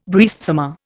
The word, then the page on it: breastsummer: Indian